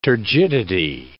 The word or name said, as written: turgidity